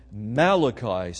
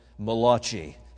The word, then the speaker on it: Malachi: Others believe this has to be Elijah because of something Malachi says in his book. So turn with me to the last book in the Old Testament. I call him the Italian prophet Malachi. His Hebrew pronunciation is Malachi.